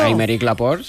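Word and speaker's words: Aymeric Laporte: Y después, Améric Laporte. – Sí. – También él es muy curiosito. – Améric Laporte, sí. – Ay, qué bien pronunciado. – Francés, claro.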